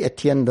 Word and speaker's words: Étienne: And I realized that I should have written this down on the handout, but it's spelled D-E-L-A-B-O-E-T-I-E, de la Boétie, Étienne de la Boétie, Étienne, E-T-I-E-N-N-E, Étienne de la Boétie.